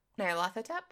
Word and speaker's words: Nyarlathotep: Nyarlathotep? Is that it? Is that how you say it? Yep.